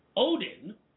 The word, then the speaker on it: Ohden: Melissa Oden, I think that's the pronunciation of her name, O-H-D-E-N, speaking on the camera about this very sensitive subject of abortion.